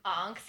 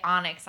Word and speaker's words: onyx: to be fair, I can see why you would pronounce it the way you did. Onks. Yeah, for some reason, like the Y was silent in my mind. Yeah. I'd never heard anybody say onyx out loud.